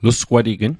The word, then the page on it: lusgwatign: Mi'gmaq